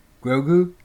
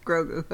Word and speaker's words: Grogu: People making like crocheted Gorgus or however you say his name. Grogu. – Grogu. Okay.